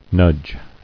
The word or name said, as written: Nudging